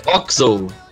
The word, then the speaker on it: OXO: Assim, ele é a pronúncia em inglês, eles falam Oxo, né, que é o X, é como se fosse um XZ para os americanos.